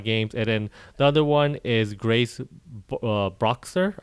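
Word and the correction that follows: Bruxner: Or I forgot how to say her name, actually. I'm looking it up right now how you say her name. But she makes a Frog Detective game. And we recently released an episode about that. Yeah, Broxner. Yeah, that's her name.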